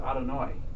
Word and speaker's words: Adonai: This word is pronounced Adonai.